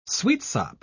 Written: sweetsop

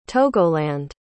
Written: togoland

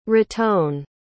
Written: raton

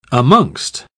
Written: amongst

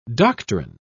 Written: doctrine

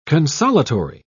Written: consolatory

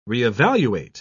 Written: re-evaluate

